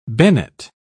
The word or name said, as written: bennet